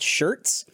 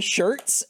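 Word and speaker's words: Schertz: I think I'm in a place called Shirts. And I didn't know that it was spelled like S-C-H, whatever. – E-R-T-Z.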